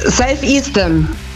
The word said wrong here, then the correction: Self-Esteem: Ja, die Aussprache ist ein bisschen... – Steam ist Steam. – Ja. Ja, ja, ist richtig. – Ja, Self-Esteem nehmen wir auch.